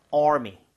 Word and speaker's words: Army: Army보다는 R가 살아있죠, R, 네. right? 저번에도 강조드렸지만, R 발음할 때 입모양 좀 모으시고 혀를 많이 당겨주세요. Army.